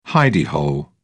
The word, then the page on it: hidey hole: British English